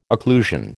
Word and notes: occlusion: American English

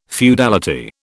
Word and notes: feudality: British English

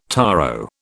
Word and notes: taro: British English

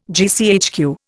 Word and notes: GCHQ: Indian

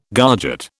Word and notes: garget: British English

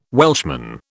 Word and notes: Welshman: British English

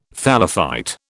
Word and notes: thallophyte: British English

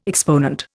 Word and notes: exponent: Indian

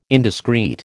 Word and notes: indiscrete: American English

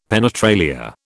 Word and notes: penetralia: British English

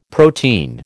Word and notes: protein: American English

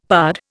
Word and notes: pard: Indian

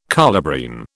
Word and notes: colubrine: British English

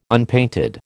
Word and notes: unpainted: American English

